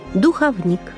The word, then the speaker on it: духовник: Хочу обратить ваше внимание, милые наши слушатели, на правильное произношение следующих слов. Запоминаем. Издревле – иконопись, знамение, вероисповедание, духовник. Наша передача «Уроки словесности» подошла к концу.